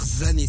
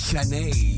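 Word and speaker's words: Zhané: Faut pas dire Zané, ça s'écrit Z-H-A-N-E, faut dire Zané.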